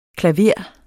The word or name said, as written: klaver